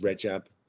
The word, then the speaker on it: Rajab: But I'm Rajab Noor, pronounced Rajab because it's an Arabic name.